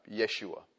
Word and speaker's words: Yeshua: In fact, Jews call Jesus either, well, see, in Hebrew, the J is pronounced as Y. So they would call him Yeshua.